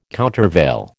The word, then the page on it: countervail: American English